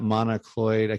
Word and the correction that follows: monoclonal: I can never these medical terms. This new treatment that Governor DeSantis has ordered. – Monoclonal. – Monoclonal, the therapies for that.